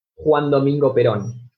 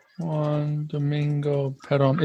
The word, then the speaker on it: Juan Domingo Perón: And can you say his name one more time? – Juan Domingo Perón. – Juan Domingo Perón. Is that P-E-R-O-N? – Yes, effectively.